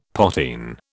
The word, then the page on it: potheen: British English